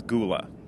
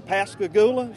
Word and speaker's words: Pascagoula: a town known as Gula. – Well, you know, it's kind of the locals say that for Pascagoula.